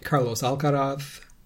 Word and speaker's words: Carlos Alcaraz: Tommy Paul beat Carlos Alcaraz. I pronounce that how Carlos pronounces it.